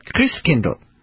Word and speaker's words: Christkindl: Now, how do you say Christ child in German, which, of course, was Martin Luther's language? Christkindl. That's right, Christkindl. What does that sound like? Kris Kringle. That's where the name comes from.